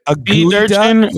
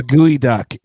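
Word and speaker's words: geoduck: So that is actually, it's called a gooey duck. – What's it called? Yeah. – It's called a gooey duck. It's called, it's spelled G-E-O duck.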